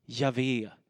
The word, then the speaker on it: Javé: Man tror att det ska uttalas Javé, därför att det finns, bara, det finns helt enkelt bara konsonanter, inte vokaler ifyllda här.